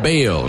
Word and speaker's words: Bello: Brian Bayo, spelled B-E-L-L-O, but pronounced Bayo.